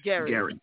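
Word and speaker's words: Gerry: I've always pronounced it gerrymandering. Maybe it's Gary. So has half the free world. But it is, in fact, Gary.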